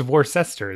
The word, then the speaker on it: Worcester: No, the C is there. No, it's we get rid of the R, C, N, E. And just... – Yeah, it's Worcester. It's missing a whole syllable.